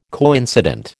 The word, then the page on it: coincident: American English